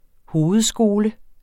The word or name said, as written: hovedskole